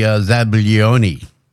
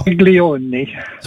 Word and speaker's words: zabaglione: You're going to talk food science, and he's talking about edible, delightful foams and Zabergonian. – That would be Zabaglione. – Yep. You there? – Oh. – Zabaglione.